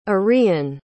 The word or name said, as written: arion